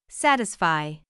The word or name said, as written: satisfy